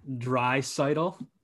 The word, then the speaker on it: Draisaitl: I'm going to put my money on Leon Draisaitl. Is that how you say it? All right, yep.